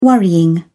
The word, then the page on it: worrying: British English